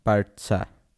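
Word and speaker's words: Barça: Barca. Barca. Barca. Ja. Habe ich es jetzt richtig ja. ausgesprochen? Die sagen das ja selber so.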